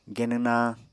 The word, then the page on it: genw nañ: Apatani